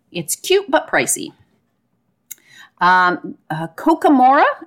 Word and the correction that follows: Kakamora: am I saying that right? – Kakamora. – I think it's Kakamora.